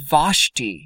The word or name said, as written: Vashti